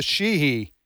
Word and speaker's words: Sheehy: And then in Montana, Sheehy. Yep. Is that the way you pronounce it? – Yep.